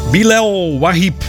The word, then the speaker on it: Bilal Wahib: Hoe spreek je het uit? Bilal Wahib. Zo heet die jongen.